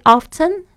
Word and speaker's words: often: Do you go to the movies very often? 或者或者是你可以说 Do you go to the movie very often? 都可以，这个 often often 都有人在用。这个发音的差别，虽然它有那个 t，但是那 t 常常是就是 silent，就是不发音的。